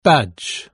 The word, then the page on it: badge: British English